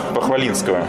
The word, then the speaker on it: Похвалинского: Да Нажимаем замок Похвалинского, если я правильно ударение ставлю. – Да.